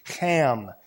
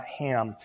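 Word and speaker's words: Ham (חם): you can pronounce his name correctly in Hebrew. It's Ham, not Ham.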